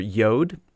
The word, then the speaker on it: Yod: In Hebrew, the letter that corresponds to the J sound in English is represented by the letter Yod, which is pronounced like the Y in yes.